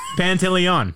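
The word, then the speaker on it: Pantoliano: I went with Guido, Risky Business, Joe Pantalonio. – Oh, yeah. – Did I say that right? – You said it really wrong. Pantaleon.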